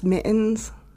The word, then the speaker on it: Mi'ens: It's pronounced mittens, like mittens without the T's.